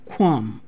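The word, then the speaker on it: gqom: It's G-Q-O-M, Kwum. It has a click in it, okay?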